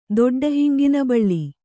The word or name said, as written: ದೊಡ್ಡಹಿಂಗಿನಬಳ್ಳಿ